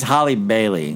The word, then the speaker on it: Halle Bailey: It's so, I mean, it's Halle Bailey. That's how they say it.